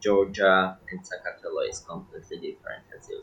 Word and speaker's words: Sakartvelo: Yeah, it's pronounced Sakartvelo.